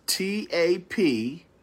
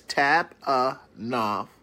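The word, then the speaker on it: TAPEINOPHROSUNE (ταπεινοφροσύνη): Okay, and the Greek word, all right, let me spell it for you, is TAPEINOPHROSUNE, and that's TAPEINOPHROSUNE, all right, I spelled it, I pronounced it very slowly for you, okay, that's TAPEINOPHROSUNE.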